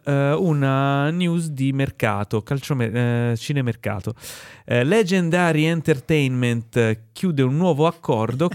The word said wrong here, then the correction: Legendary: non sapendo dove mettere l'accento l'hai messo su tutte le vocali leggendo legendary. no, è legendary.